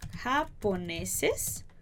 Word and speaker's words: japoneses: Los. Ha. Lembra aquele ha aqui na garganta? Ha, ha, ha. Japoneses. Ha. Po. Ne. Ses. E aí, japoneses son. Então, esse S vai virar um S só. Japoneses son.